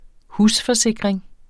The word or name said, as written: husforsikring